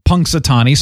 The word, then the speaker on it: Punxsutawney: That was the most bizarre thing ever, but it is Punxsutawney, so we were originally right.